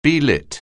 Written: BLitt

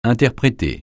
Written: interpréter

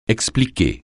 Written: expliquer

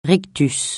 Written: rictus

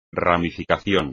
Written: ramificación

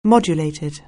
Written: modulated

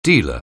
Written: dealer

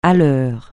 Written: à l'heure

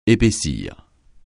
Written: épaissir

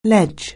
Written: ledge

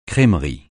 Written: crémerie

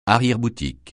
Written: arrière-boutique

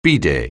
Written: bidet

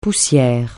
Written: poussière

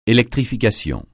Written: électrification

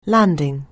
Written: landing

